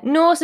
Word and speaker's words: Norse: N-O-R-E. No, N-O-R-S-E-M-E-N. – Yeah, Norse as in like, you know. – Horse. – No. – Norse as in horse and men as in them.